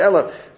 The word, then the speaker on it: Aleph: Aleph, we pronounce it Aleph.